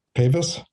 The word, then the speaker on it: Pavis: Pavis.